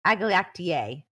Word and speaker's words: agalactiae: So we're talking about group B streptococcus or Streptococcus agalactiae.